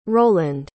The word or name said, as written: rowland